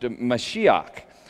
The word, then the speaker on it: Mashiach: In Hebrew, anointed is pronounced Mashiach.